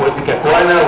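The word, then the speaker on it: Ipecacuanha: واسمه ايبيكاكوانا والاتش لا تنطق يعني عشان برضه تشوفوا محدش يزعلك في حاجه.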